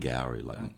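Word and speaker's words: Angourie: Angowri, like Maori.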